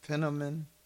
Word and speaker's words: Penniman: Richard Wayne, I think it's pronounced the last name Penniman.